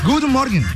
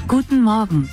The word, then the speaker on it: Guten Morgen: Guten Morgen. Escuchemos cómo se dice buenos días en alemán. – Guten Morgen. – Eso, sí. Guten Morgen. Dígalo usted, por favor.